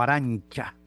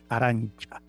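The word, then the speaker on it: Arantxa: Esta sentencia condena a Sánchez Vicario, Aranza Sánchez Vicario, o Arancha, como se pronuncia más bien, se escribe con X, Arancha Sánchez Vicario, 52 años de edad, condenada a 2 años y prisión, 2 años de prisión y multa.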